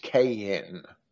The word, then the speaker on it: Kajin: It says in part, Cain, and he spells it K-A-J-I-N, which should be pronounced Cain, like two syllables.